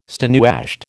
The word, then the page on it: stonewashed: American English